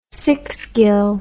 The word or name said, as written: sixgill